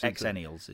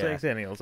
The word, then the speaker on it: Xennials: Yeah, but Nick and I are Xenials, so it's Xennials. Is how. – Is it pronounced Xennials? Okay.